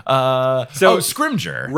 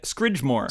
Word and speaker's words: Scrimgeour: So I was writing a song at one point, and somebody had to point out to me that I was not pronouncing the Minister of Magic previous to this one's name correctly. – Fudge? – No. – Oh, Fenrir. No, not Fenrir Greyback. What's the lion guy? – Yeah. – Scrimgeour. – Right. I was pronouncing it Scridgemore.